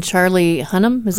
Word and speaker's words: Charlie Hunnam: And then Charlie Hunnam, that how you say his name? – Yep.